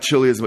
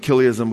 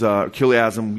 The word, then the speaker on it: chiliasm: The early church fathers were, for the most part, they believed in what's called Kiliasm with a C-H. Okay? Not Chiliasm, but Kiliasm, which was Kiliasm, we can pronounce it that way.